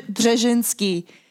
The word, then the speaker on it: Brzezinski: Brzezinski, Brzezinski.